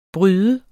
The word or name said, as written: bryder